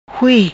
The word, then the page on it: Hui: patua